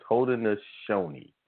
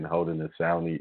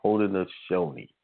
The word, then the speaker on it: Haudenosaunee: It's Haudenosaunee is how it was pronounced. I think I was saying Haudenosaunee. It's Haudenosaunee. That is correct pronunciation of those people of the Longhouse.